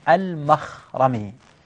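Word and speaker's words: المَخْرَمي: المخرمي بفتح الميم وليس بضمها وسكون الخاء لا بفتحها، زين، وبفتح الراء لا بتشديدها، واضح؟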